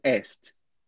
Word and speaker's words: asked: No seu caso, né, em inglês americano, asked. Tá? A gente não pronuncia.